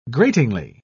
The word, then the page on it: gratingly: British English